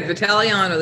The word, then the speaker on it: Vitagliano: You did pretty good. Vitagliano, the G is silent, but that's really close.